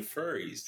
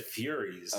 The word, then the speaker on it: furries: You said furies. – Okay. – Furries. Like, you know, the Greek.